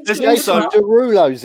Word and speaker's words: Jason Derulo: Jason Derulo's in it. Well, no, he'll come on singing his own name. – No, say it properly. It's Jason Derulo.